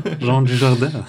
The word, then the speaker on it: Jean Dujardin: Wir haben einen Namen richtig ausgesprochen. – Ja, Jean Dujardin.